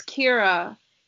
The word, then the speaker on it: Ciara: It's Kira. Yeah. C-A-R-A. – I think it's C-I. It looks like Sierra. C-I-A-R-A.